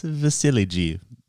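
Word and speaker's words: Vasilije: How do we pronounce Vasiliji? Is that correct? – I'm gonna go ahead and stick with that. – Okay, Thunder fans, until we know different, it's Vasiliji.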